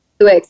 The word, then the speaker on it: Swizzix: Squigs? – Swizzix. Like Twix, but with an S.